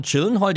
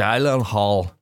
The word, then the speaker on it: Gyllenhaal: Nee, nie drüber nachgedacht, aber der Name Gyllenhaal, der übrigens ganz anders ausgesprochen wird in Realität, alle sagen Gyllenhaal, aber der wird wohl, die Vokale werden wohl so betont, dass Jake selbst in Talkshows sagt, wir bleiben bei dieser Aussprache. Gyllenhaal ist total okay, versucht's lieber gar nicht erst. – Ich möchte aber jetzt wissen, wie es ausgesprochen wird. – Gyllenhaal. So, man muss irgendwas Komisches machen mit seinen Stimmorganen.